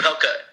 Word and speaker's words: Opelka: And Opelka, so is it not like a possum where the O is silent? You actually say the O in this one? – I do.